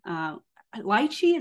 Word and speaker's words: Lychee: Lychee, is that how you say that? – Yeah.